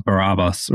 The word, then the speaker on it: Barabbas: And then to the name, you pronounced it quite well, Barabbas, right? So let me kind of put a little more emphasis on it. Barabbas, Bar-Abba. The name means son of the Father, and Matthew's Gospel gives us his full name, Jesus Barabbas.